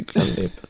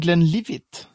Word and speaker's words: Glenlivet: Men det heter ju Glenlivet. ja. Orökigt. Uttalar jag det rätt? – Eh, ja, alltså jag tror ibland så hör man det nästan som Glenlivet. Alltså med, som, som levde.